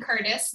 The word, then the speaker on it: Curtice: So I share amazing content as well on my, it's just Colleen Curtis. My last name is spelled C-U-R-T-I-C-E. Usually people want to put I-S.